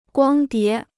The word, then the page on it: 光碟: guāng dié